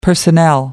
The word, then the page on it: personnel: American English